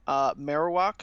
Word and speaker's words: Marowak: And they have Meowth Dark type, Marowak, is that how you pronounce it? – Yeah.